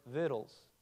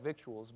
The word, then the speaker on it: victuals: It has this word that's spelled V-I-C-T-U-A-L-S. That's a good old country word, victuals. I know it looks like victuals, but it's victuals.